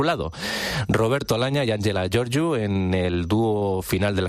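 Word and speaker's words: Gheorghiu: Ahí sonaban las voces de Roberto Alagna y Angela Georgiu, o Georgiu, como se pronuncia en rumano, el apellido de esta soprano, que bueno, en el momento en el que grabaron este registro discográfico, pues eran pareja, eran matrimonio.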